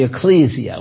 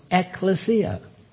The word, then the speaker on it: ekklesia: The word church in the Greek language is ekklesia. People call it the ecclesia. Well, it's actually pronounced ekklesia.